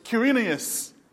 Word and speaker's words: Quirinius: Quirinius. Quirinius was governor of Syria.